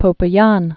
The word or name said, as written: Popayan